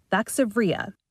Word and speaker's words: Vaxzevria: Here are the names properly pronounced. – Pfizer's COVID-19 vaccine will now be known as Comirnaty. Moderna will be called the Spikevax, and AstraZeneca's will be called Vaxzevria.